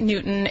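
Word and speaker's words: NWTN: But no, they pronounce it Newton.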